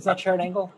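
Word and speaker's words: Sharron Angle: You'll remember in 2010, Sharon Angle, who ran against Harry Reid in Nevada, Sharon Angle? – Yeah, you said it right.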